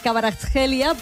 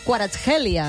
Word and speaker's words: Kvaratskhelia: Mira, durant anys li hem estat dient Kvaratskhelia, però es veu que es diu Kvaratskhelia. Ens ho comentava l'altre dia el Gerard Otet, que tenia un conegut... – Kvaratskhelia, sí.